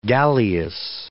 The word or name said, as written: galleass